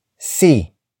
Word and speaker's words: si: The next word is See. See. See. See is spelled as S-I.